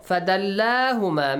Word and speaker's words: فَدَلَّاهُمَا: So, فَدَلَّاهُمَا. Make sure you pronounce the alif there.